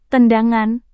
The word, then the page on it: tendangan: indonesian